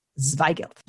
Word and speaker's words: Zweigelt: which was Zweigelt, which is a word I love to say, and is in this category of lighter red wines from sort of the more Germanic regions of Europe. Zweigelt.